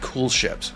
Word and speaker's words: koelschip: But one of the cool things about this, about making a lambic, is that they use these things called cool ships. And it's not spelled C-O-O-L. It's spelled K-O-E-L-S-C-H-I-P.